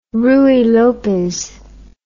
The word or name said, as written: ruy lopez